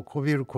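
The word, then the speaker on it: 小昼: このちょっと下に書いてある、これからハウスでの作業、少しでも体を冷やすために、はい、小さい昼と書いてこびりって読むんですかね。 – はい、私たちはあの、うん、おやつのことをこびるこびるって言ってますけどね。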